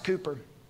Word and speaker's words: Cowper: William Cowper, spelled Cowper, pronounced Cooper.